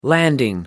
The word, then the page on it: landing: American English